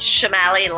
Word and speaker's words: Shamali: It's Shamali, like an S, sorry.